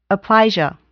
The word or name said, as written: Aplysia